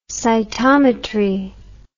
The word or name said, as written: cytometric